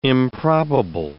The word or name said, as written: improbable